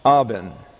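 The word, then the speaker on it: eben: The word for stones is aben. That's E-B-E-N, pronounced aben.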